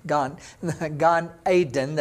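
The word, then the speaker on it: Gan Eden (גן עדן): You'll remember in the Garden of Eden, Gan Eden, that's how we pronounce it, be a reader in Hebrew.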